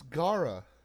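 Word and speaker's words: Gaara: Is it Gara or Gara? – Gollum? – It's Gara. – Okay, so it's not a pronunciation thing.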